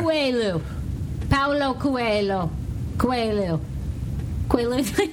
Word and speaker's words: Coelho: So his name is Paulo Coelho.